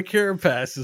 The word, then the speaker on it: carapace: So the cooldown on the carapace is, did I say that right? – Carapace. – Yeah. – I mean, you were close. You put emphasis in a weird way that made it seem dirty.